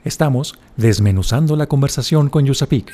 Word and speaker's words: USAPEEC: Yusapik se escribe U-S-A-P-E-E-C.